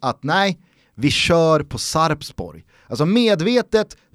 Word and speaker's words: Sarpsborg: Eh, varför sitter de och säger Sarsborg när det är ett P-namnet? Säg P, uttala P så slipper folk rasa.